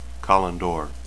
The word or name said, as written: callandor